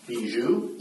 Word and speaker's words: Bijoux: Bijou? – Yes.